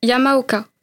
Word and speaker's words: Yamaoka: Je m'appelle Nana Yamaoka. – Yamaoka, ça s'écrit comment ? – Ça s'écrit Y-A-M-A-O-K-A.